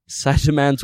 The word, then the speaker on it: Saitama: And that is Saitama's workout.